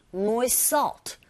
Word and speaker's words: moist salt: moist, 안 들려요. moist salt. 이렇게 들리고 발음하시면 됩니다.